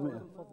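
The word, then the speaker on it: مائة: بس ملاحظات سريعة إذا أمكن، يقال كلمة مئة تكتب مئة وتلفظ مئة، في كل سنبلة مئة حبة، ما كان في وقت، يقال حتى يميز بين كلمة مئة ومنه فكتبه مئة هكذا.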